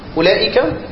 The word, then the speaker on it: أولئك: أولئك وأولاء وأولى الواو، انت لم تنطق الواو ولا كلمة من دول، انت ضميت بس. تمام. انت نطقت الضمة فما مدتش، طب انت كتبت واو ليه؟ فهي حرف زائد.